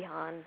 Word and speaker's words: Haun: My band is called the Han Solo Project, Lindsey Haun, Han Solo Project. It's spelled like my last name, H-A-U-N, so we can get away with it.